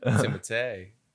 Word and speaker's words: Timothée: Timothée.